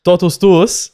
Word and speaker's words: Totus tuus: And this segment is now sponsored by our friends at I always botch this pronunciation because I can only say it in Latin with the Latin accent. So I'm just going to say it with the Latin accent. Do it. – Totus tuus.